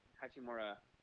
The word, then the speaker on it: Hachimura: I'm asking the reasoning why they picked Hachimura. Am I saying that right? – Oh, my God. Yes.